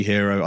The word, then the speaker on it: Hero: It's Andy Hero. I spell my name wrong. Apparently, it's A-N-D-I. – Is that H-E-R-O.